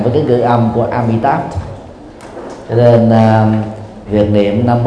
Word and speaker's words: Amitabha: Còn nếu mà muốn đọc sát thì chúng ta đọc là Nam Mô Amitabha Buddha.